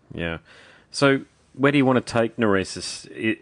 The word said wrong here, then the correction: Nereis: How do you say the name of the band? – So, like, in the Italian pronunciation, it will be Nereis. – Nereis. – But, like, in English, you can say Nereis.